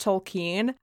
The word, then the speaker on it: Tolkien: So I believe it is pronounced Tolkien.